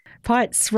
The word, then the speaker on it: Pites: You've had some of my very good friends on, in fact, but I loved your podcast with Chelsea Pites rhymes with lights. That's what she said to me, so I remember it now.